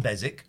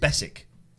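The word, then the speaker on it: Beswick: I listened to the excellent Alan Bezick, or apparently his name is pronounced Bessick.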